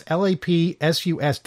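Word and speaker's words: Lapsus$: It's LAPSUS dollar sign.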